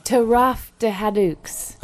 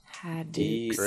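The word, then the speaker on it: Taraf de Haïdouks: Taraf de Hadux. – Oh, okay. – T-A-R-A-F. – T-A-R-A-F.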